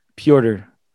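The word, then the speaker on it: Petr: Yeah, but it's just how do you say his first name? – Piotr. – Piotr? It's not so Peter? – People call him Peter, but like...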